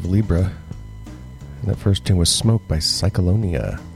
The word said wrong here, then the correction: Cyclona: Or, I'm sorry, Cyclona. Mispronounced that.